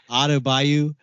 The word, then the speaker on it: Adebayo: Yeah, he's Adebayo.